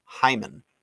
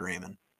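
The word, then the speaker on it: Raimann: I actually just learned that his name is actually pronounced Hyman. – Yeah, it's spelled with an R, so. – It is, but he is Austrian. I did not know that it was pronounced Hyman either. I always said Raymond.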